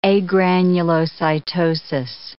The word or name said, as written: agranulocytosis